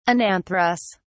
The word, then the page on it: anantherous: British English